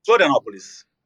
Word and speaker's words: Florianópolis: Florianópolis.